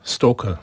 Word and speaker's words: stalker: Stalker. – Mówi się po angielsku stalker.